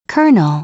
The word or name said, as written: colonel